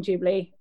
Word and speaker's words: Ghibli: So apparently, and also I'm now saying Ghibli. I was saying Ghibli before. – Okay. – No idea how to say it.